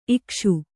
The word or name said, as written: ಇಕಿಸು